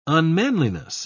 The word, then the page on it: unmanliness: British English